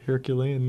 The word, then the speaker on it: Herculean: That was Herculean. – I like that. Good. You pronounced it the way I was going to. I don't know. Herculean.